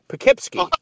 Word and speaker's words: Poughkeepsie: I said, "Bill, it's Poe," and he said Poe, and I said Kip, he said Kip, and I said C, and he said C. Poughkeepsie. Still said it wrong.